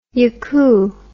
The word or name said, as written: yakoo